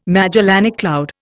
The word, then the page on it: Magellanic cloud: Indian